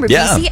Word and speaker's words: Ribisi: Ribisi? – Yeah.